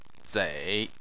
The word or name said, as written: zei3